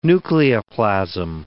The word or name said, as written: nucleoplasm